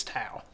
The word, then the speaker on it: Taos: Literally, T-O-W, Taos. – Nice. – It's spelled toe, but it's Taos.